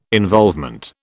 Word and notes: involvement: British English

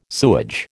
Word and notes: sewage: American English

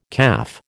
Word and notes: CAF: American English

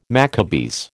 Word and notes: Maccabees: American English

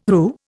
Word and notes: RU: Indian

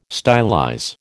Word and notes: stylize: American English

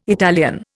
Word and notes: Italian: Indian

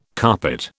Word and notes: carpet: British English

